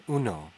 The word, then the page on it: uno: /ˈu.no/